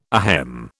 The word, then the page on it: ahem: British English